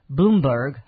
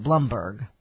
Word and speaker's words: Bloomberg: I heard a Bloomberg, I'm sorry, he pronounces it Blumberg, commercial this morning on the radio as I was getting ready to come to the office here in the studio.